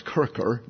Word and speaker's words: Kircher: And Athanasius Kircher, actually it's pronounced Kircher, the CH gets the K sound.